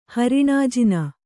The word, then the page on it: ಹರಿಣಾಜಿನ: kannada